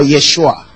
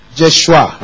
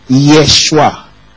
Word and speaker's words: Yeshua: Jeshua, right? Or Yeshua. But you replace the J with the Y.